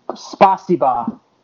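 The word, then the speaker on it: spasiba (спасибо): Pronounced spasiba. No, that translation is God save you. Fuck. Or thank you. It means thank you. So God save you in Russian means thank you. – So okay. – Spasiba.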